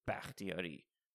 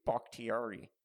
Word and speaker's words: Bakhtiari: They're missing their left tackle, David Bakhtiari. That's how you say it, you cunts. These people on fucking Fox. David Bakhtiari.